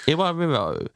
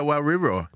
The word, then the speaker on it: Iwariro: Main, Iwariro. How was that? – Yeah. Bang on. – I said that right? – Iwariro, yeah. – With caramelized plantain. Let's hear it again. – Iwariro.